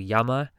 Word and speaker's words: Yaima: It's a new song by the artist called Yama. So you pronounce it, basically, you spell it as Y-A-I-M-A. So that's Y-A-I-M-A.